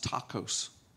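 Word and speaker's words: tacos (τάχος): The Greek word there, you're going to love this, it's pronounced tacos. Spelled differently.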